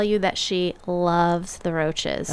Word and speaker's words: Roches: Is that how you say it? – The Roches. – Roches. Okay, sorry.